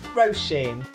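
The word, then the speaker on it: Róisín: So the correct way to pronounce her name is Roisin, like row your boat and Mr. Sheen. Roisin.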